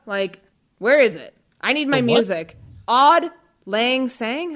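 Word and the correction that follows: Auld Lang Syne: It's What's wrong with my pronunciation? – It's Old Lang Syne.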